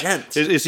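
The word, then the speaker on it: Djent: Is that how it's Gent. Pronounced? – Yeah, yeah. Gent.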